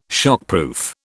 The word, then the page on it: shockproof: British English